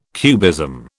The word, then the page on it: cubism: British English